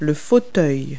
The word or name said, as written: armchair